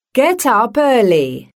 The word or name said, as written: get up early